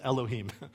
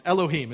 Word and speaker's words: Elohim: That's how you pronounce those squiggles, is Elohim. So that's the Hebrew in the bottom, and I can't read that either, but if you could, or maybe some of you could, it's Elohim is how you say that, right?